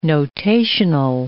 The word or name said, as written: notational